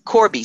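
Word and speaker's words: Corbie: Yes, Corby, C-O-R-B as in boy, I-E.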